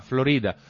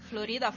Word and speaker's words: Florida: C'era un locale che si chiamava il Florida e che ci teneva a dire che c'era l'accento sulla, sulla I. – Eh, me. perché, sì, Florida va bene, Florida è la pronuncia spagnola, ma vabbè, sono un rompiscatole, lo so, ma in realtà la pronuncio indistintamente, non ci tengo per niente.